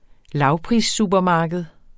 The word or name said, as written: lavprissupermarked